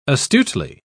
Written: astutely

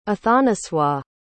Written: athanasois

